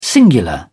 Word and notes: singular: British English

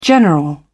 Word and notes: general: British English

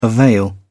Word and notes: avail: British English

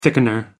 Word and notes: thickener: American English

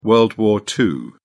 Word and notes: world war ii: British English

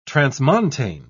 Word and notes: transmontane: British English

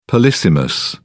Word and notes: polysemous: British English

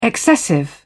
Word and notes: excessive: British English

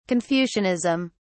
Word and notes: confucianism: British English

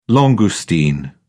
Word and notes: langoustine: British English

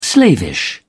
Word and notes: slavish: British English

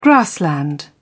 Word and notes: grassland: British English

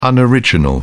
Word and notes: unoriginal: British English

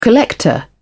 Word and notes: collector: British English

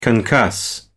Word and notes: concuss: American English

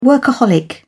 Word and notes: workaholic: British English

